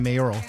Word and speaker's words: mayoral: I finally said it right that time, mayoral.